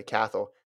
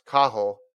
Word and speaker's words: Cathal: He said, my fellow Irishman, Cathal Dennehy, which is what you and I say, Cathal, is pronounced Cahill.